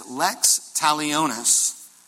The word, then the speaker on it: lex talionis: In Latin, it is lex talionis.